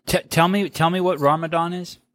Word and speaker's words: Ramadan: Yeah, Ramadan. It's the D, you can say it like a TH. – Ramadan. – Like Ramathon.